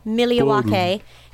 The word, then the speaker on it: Milwaukee: The Milwaukee, that's how you pronounce it, by the way, Milwaukee County Transit System shared surveillance footage of the bus driver.